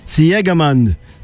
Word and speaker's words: Siegemund: You pronounce it Siegemund, 6-1, 6-2.